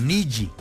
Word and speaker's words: Nilly: N-I-N-I-L-L-Y, como Niji, como si uno escribiera Niji. N-I-L-L-Y, Barrios, ¿sí?